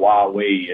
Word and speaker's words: Huawei: They have it into our communications, to the company that's called Huawei. It's not spelled like that, but that's how you pronounce it.